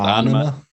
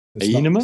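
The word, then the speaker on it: Ænima: I'm going with anima. – Yeah. Anima. – Enema? some people get it wrong and say enema, but it's anima.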